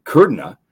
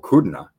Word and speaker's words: Kudrna: Ben Kudrna, I believe that's right, or Kudrna. Kudrna? I actually had an interview with him,